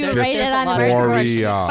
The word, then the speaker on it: dysphoria: Okay, so trans in general, people say that that's body dysphoria. Right? Did I say that correctly? – Yeah, dysphoria.